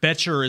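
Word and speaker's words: Bettcher: I believe it's James Betcher. – Is how you pronounce it.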